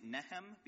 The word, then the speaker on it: Nihm: I'm going to be using the more common translation used by scholars, which is N-I-H-M, and pronouncing it Nehem, because that's how I understand locals tend to pronounce the name in Yemen.